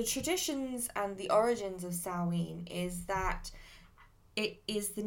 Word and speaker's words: Samhain: So the origins of Halloween lie in the pagan celebration of Samhain, which is actually, it's actually spelt, you may have seen the word around, it's actually spelt Samhain, but it's pronounced Samhain, which is where today's word Halloween has become.